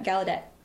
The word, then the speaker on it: Gallaudet: I remember a few years ago, I don't know, 10 years ago, there's a major deaf university, Gallaudet, am I yeah. – pronouncing that right? – Yep, Gallaudet.